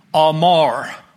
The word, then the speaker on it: amar: Verse 17, the word of vouch, not commonly used, is a Hebrew word which is pronounced amar.